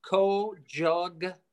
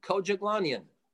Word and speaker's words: Kojoglanyan: So it's 11 letters long, and it's pronounced the way it's spelled, Kojoglanyan, Kojoglanyan.